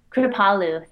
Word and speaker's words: Kripalu: Kripalu.